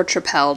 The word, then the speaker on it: Tripel: It's by Victory called the Sour Monkey Sour Triple or Tripel, depending on how you want to pronounce that. I had heard with the emphasis on the L before.